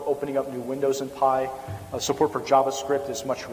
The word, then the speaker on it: PIE: They can have what's called Pocket Internet Explorer, which is abbreviated PIE and generally pronounced PI.